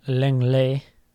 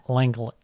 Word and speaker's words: Lenglet: Tottenham announcing today they signed Clement Lenglet. – Yeah. – Or however you say his name. I don't speak French. – Leglet. – Lenglet.